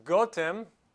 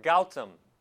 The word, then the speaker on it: Gotham: The history of Gotham, the word is pronounced Gotham or Gautam.